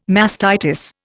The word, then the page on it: mastitis: Indian